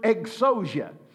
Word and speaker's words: exousia: It is the Greek word exousia. If you're taking notes, it's spelled E-X-O-U-S-I-A, exousia.